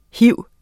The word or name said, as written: hiv